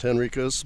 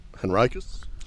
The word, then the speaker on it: Henricus: And you noticed that John mentioned that the original name was probably pronounced Henricus by the colonists, but the modern preferred pronunciation, I understand, is Henricus? – Well, it's Henricus, and I think a lot of that has to do with Henrico County. – Yeah. – And Henricus is probably the proper pronunciation, given that it was named after someone named Henry. But today, everybody knows it as Henricus.